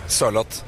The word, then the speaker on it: Sørloth: How are you supposed to say? Sørloth? Sørloth? How do you say that? – A bit slower. Sørloth.